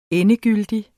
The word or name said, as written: endegyldig